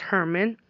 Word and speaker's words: German: And right after graduation, my boyfriend, his name was Herman, or German. It's spelled G-E-R-M-A-N, but pronounced Herman.